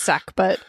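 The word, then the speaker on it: sackbut: It's pronounced sack butt. I think it's spelled S-A-G-B-U-T.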